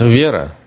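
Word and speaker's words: vera (вера): Oh, that's vera.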